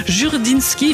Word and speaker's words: Jurdinski: On écoute le gérant du magasin, Étienne Jurdinski, à la française. Du Jurdinski. Jurdinski, il faut le prononcer à la française.